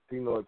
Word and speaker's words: Pinoy: So, DJ Nasty Ness, I noticed a tattoo, like you had a tattoo on your forearm, right, that says Pinoy Pride. And am I pronouncing that right? – Yeah. Pinoy means Filipino.